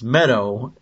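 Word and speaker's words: Mehdoh: So I didn't get it, but I guess it's Meadow. And I mean, I actually figured, you know, out that it's pronounced Meadow before last week, but I still didn't make the connection.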